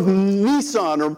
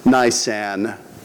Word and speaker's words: Nisan: Maybe it was the month of Nissan or Mazda. No, actually, here's the correct pronunciation on that. It's actually pronounced Nisan. And it's a month in the Hebrew calendar.